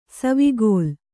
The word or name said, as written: ಸವಿಗೋಲ